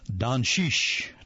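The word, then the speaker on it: Dončić: His last name is spelled D-O-N-C-I-C with a couple of little things above the two C's, and found out later that it's actually pronounced Doncic. Doncic.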